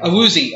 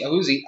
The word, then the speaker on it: Awuzie: Chidobe Awuzie, that's I think Awuzie. Correct. Awuzie, Awuzie, and Jordan Lewis.